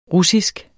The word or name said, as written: russisk